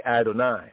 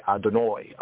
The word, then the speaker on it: Adonai: Others will say Adonai, Adonai, Adonai, right? Or Doni, Adonai. Some Yehudi will say Adonoi, Adonoi, in their particular pronunciation, Adonai.